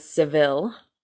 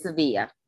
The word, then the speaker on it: Sevilla: We are in Sevilla, the capital of Spain. If you don't recognize that, us filthy Americans usually pronounce it as Seville. – No, it's Sevilla.